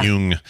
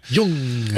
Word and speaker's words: Jung: Sorry, yeah. Jung. – Jung. – It's spelled J-U-N-G.